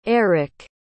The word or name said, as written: aric